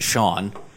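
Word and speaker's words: Cian: How, Sean? – I believe that's how it would be pronounced. It's C-I-A-N. – Yeah, in a country that should... – Yeah, I think it's Sean.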